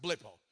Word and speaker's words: blepo: It's spelled B-L, short E, P-O. You pronounce it blippo, which is just to see something with your natural eyes.